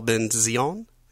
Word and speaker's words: Benzion: And we also have uh, a couple of other guests uh, Françoise Yip as Raquel Ben Zion, Benzion. It's just a Z. It's a silent T.